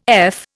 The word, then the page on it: eff: Indian